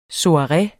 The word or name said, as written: soireer